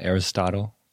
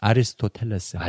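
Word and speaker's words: Aristotle: 그런 장면 나오는데 서양에서도 그 어, 에리스토텔, 플레이토. – 어, 그치 아, How do you say those in Korean? – 아리스토텔레스.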